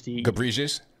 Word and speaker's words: Ghebreyesus: Ghebreyesus? – That's it.